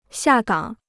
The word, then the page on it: 下岗: xià gǎng